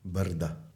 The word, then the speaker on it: Brda: How do you pronounce that? – Brda. Yes. – But that's how you say in Slovenian? – It's Brda? – It's the hills.